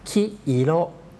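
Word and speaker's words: คิ อิ โร (きいろ): ไฟจราจรคำว่าคิโรแปลว่าสีเหลืองนะครับออกเสียงช้าๆชัดๆจะออกเสียงว่าคิอิโร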